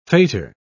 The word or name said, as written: faitour